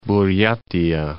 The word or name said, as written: Buryatia